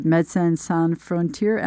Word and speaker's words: Médecins Sans Frontières: So we'd be really curious to hear from your perspective, what have been the major changes within Doctors Without Borders, or Médecins Sans Frontières, MSF, as you've referred to it? I hope I've said that correctly. – You did. Thank you.